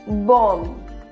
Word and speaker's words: bomb: And the bomb, no, it's bomb. It's bomb. Last B is silent.